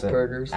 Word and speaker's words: Asperger's: Yes, she has Asperger's, right? That's how you pronounce Asperger's.